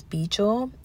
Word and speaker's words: Baegel: His name is Beejul, and that's spelled B-A-E-G-E-L.